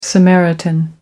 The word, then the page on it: samaritan: American English